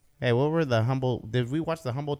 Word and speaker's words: Humble: It's Humble, by the way. Humble Texas. – No. It doesn't deserve. – That's how it's always pronounced.